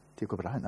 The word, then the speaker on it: Tycho Brahe: Tycho Brahe, did I pronounce it correctly? Tycho Brahe, no, not bad. For a person who doesn't speak any Danish at all.